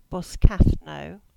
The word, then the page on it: Boscathnoe: English